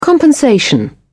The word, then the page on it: compensation: British English